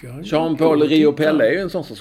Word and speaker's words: Jean-Paul Riopelle: Jag vet inte hur det ska uttalas. Jean-Paul Riopelle. Säg det noga. Jean-Paul Riopelle. Rio-Pelle.